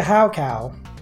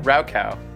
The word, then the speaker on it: Raocow: That would be a Haocow. – Haocow. – A Haocow. R-A-O-C-O-W. – Oh, Raocow. – Yes. I pronounce it French because I am French. – Is it? I thought it was Raocow. – Well, Raocow is fine.